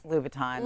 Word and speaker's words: Louboutins: Louboutins. – Louboutins, yeah.